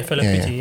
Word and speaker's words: Fela Kuti: Isn't it Fela Kuti? – Yeah, Fela Kuti.